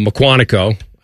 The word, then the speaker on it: Mukwonago: Uh, Mukwonago. I believe I pronounced that correctly.